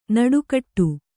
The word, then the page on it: ನುಡಿಗೊಡು: nuḍigoḍu